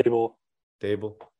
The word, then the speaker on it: Daboll: How do you say his name? – Dable.